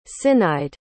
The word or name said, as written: cinneide